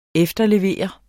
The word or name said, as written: efterlevere